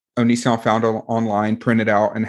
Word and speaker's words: Onision: Onision.